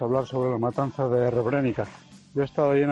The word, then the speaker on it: Srebrenica: y me dijo que se dice Srebrenica. Yo lo he dicho mal, se dice Srebrenica.